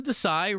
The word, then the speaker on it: Desai: So Sean Desai rhymes with I hope he's not high.